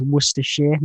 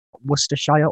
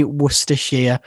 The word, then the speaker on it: Worcestershire: It's the correct way of pronouncing it, just so anyone listening wants to know, is Worcestershire, not Worcestershire or Worcestershire. It's Worcestershire. That's how we pronounce it anyway.